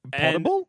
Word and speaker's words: potable: Potable? – Potable. – Potable? – Yeah. – Is it pronounced potable? – Potable. Yeah.